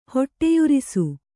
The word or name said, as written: ಹೊಟ್ಟೆಯುರಿಸು